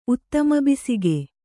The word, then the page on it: ಉತ್ತಮಬೀಸಿಗೆ: kannada